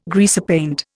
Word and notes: greasepaint: Indian

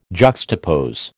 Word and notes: juxtapose: American English

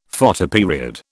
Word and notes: Photoperiod: British English

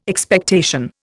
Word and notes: Expectation: Indian

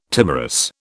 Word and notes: timorous: British English

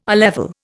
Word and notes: A level: Indian

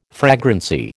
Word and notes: fragrancy: American English

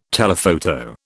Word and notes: telephoto: British English

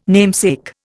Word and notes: namesake: Indian

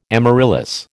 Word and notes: amaryllis: American English

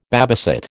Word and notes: babysit: American English

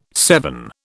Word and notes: seven: British English

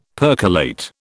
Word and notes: percolate: British English

